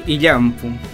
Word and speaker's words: Illampu: Sí, Illampu.